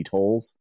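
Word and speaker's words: Toles: I have a Facebook page for my law office, which are the law offices of Cassidy Tolles, C-A-S-S-A-D-Y, T as in Thomas, O-L-E-S.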